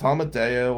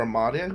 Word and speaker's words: Amadeo: Do it, is it Amudio? And I'm like, well, it's fine. I don't know. And he's in love with saying my name. It's Amadeo or Amadeo, who gives a shit, right?